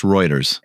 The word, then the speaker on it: Reuters: Reuters. That's how I've always pronounced it. – It is pronounced Reuters. – Okay, cool. Yes. I know a lot of people think that it's Reuters. – No. – It is not. It's Reuters. – It's German.